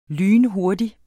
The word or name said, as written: lynhurtig